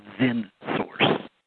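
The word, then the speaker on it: XenSource: They bought a product called ZenSource. – Spell that. – X-E-N.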